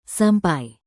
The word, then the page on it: sampai: indonesian